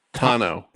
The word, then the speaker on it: Tano: Tano. – Tano. What do you think of this call? Is this like... Because this is outside my realm. – Ahsoka Tano, it was a character that was created for the Clone Wars cartoon.